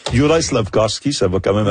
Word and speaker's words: Juraj: Personnellement, moi, j'ai de la difficulté avec Jurav Slavkovski. Oui, Juraj.